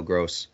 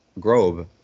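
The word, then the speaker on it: Groß: I knew it was gross, but I, uh, obviously you mistake the B for a B- Grobe, and uh, it can be mistaken.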